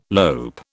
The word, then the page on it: lope: British English